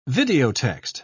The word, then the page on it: videotext: British English